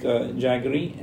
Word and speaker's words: jaggery: But jiggery, I never know how to say it. That's okay for everyone to have, right? As a sugar replacement. – As a refined sugar replacement, you can go with, like, jaggery and...